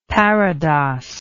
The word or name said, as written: parados